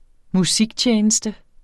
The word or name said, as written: musiktjeneste